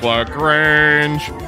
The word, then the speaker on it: LaGrange: There's Dominique LaGrange, which rhymes with strange.